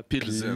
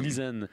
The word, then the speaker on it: Plzeň: Essaye de prononcer ça comme il le faut, toi. – Plzeň. – Plzeň. Plzeň. Plzeň. – Plzeň. – Plzeň. C'est comme une explosion de consonnes dans le micro.